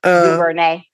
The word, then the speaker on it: DuVernay: DuVernay.